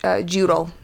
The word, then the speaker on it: Jotul: Jutul, I believe is how it's pronounced. – J-O-T-U-L.